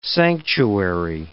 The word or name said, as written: sanctuary